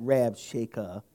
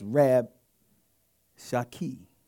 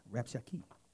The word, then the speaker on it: Rabshakeh: This has to do with, you probably heard pronounced the wrong way, Rab Shaker, but it's not Rab Shakeh. It's Rab Shaki. Yeah, Rab Shaki. Or Rab Shaka. You can just depend you northern kingdom or the southern.